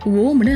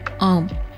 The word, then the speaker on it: AUM: ஓம்னு ஆயிடுச்சு அது ஓம் இல்லைங்க ஆம் ஆ உ இம் இந்த மூன்று சப்தங்களும் ஒரே கால அளவுல சேர்ந்து வரும்போது தான் அது ஆம் ஆகுது